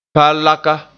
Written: palaka

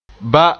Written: ba

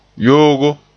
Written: yoogo

